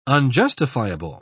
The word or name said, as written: unjustifiable